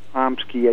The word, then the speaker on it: Hominsky: I talked to one of the family guys, and he said that, yeah, he says, you know, it's spelled Homsky, H-O-M-S-K-I, and it's also spelled like Hominsky, which is pronounced Homsky.